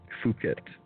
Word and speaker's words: Phuket: Phuket Tie. Phuket, I believe, is the proper pronunciation.